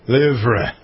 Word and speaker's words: Louvre: Don't pronounce the R-E on it, either. – Louvre. – Louvre. – The Louvre.